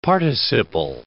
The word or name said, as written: participle